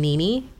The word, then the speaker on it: Nini: Nerosa is just a mixture of my nickname, Nini, but it's spelled N-I-N-I.